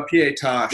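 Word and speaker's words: pietà: So for those who are listening, we can't dwell too long because some people are just listening, but we're looking at a pieta, is it? Is that how you pronounce it? – A pieta.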